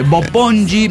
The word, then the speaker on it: Bopongi: Bopongi Ranch. Pero luego dicen cómo lo pronuncias, no entiendo ni mergas. Bueno, B de burro, O-P-O-N-G-I.